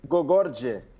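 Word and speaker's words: Gorgodze: Ô, ô, eu lembro que você tinha aprendido a pronúncia correta do nome dele. Como é que é mesmo? – Gogorje. – Gorgote.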